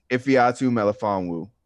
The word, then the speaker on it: Ifeatu Melifonwu: Ifeatu Melifonwu.